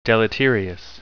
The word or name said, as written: deleterious